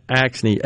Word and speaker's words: Axne: I don't know how to pronounce her last name there. – Rhino. Axne? – Axne, A-X-N-E, Representative Axne.